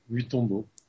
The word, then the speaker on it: Mutombo: Grégory, alors comment on dit ton nom déjà Comment ça se prononce vraiment Mutombo. – Mutombo, juste comme ça, comme ça se prononce.